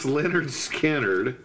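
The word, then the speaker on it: Lynyrd Skynyrd: Trace Ombres, Allman Brothers, Brothers and Sisters, Leonard Skinner, pronounced Leonard Skinner, Goat's Head Soup, let me see, Goodbye Yellow Brick Road, and Sabbath Bloody Sabbath.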